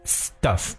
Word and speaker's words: stuff: 而我们首先来看一下这个单词 stuff，它的发音呢是比较短的，而 stuff，所以呢不要把它拖很长，不然呢就变成另外一个词汇了。